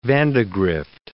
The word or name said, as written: Vandegrift